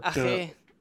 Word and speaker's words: Ache: Äh, Ragnar Acke, Acke, Acce, Ache? Also, Ache.